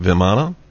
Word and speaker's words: Vimana: I think they're pronounced Vimana. – Yes.